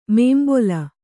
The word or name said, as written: ಮುಂಬಲಿ